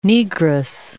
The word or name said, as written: Negress